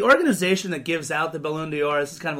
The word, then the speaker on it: Ballon d'Or: Ballon d'Or? – Thank you.